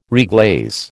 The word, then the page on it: reglaze: American English